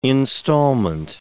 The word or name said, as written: installment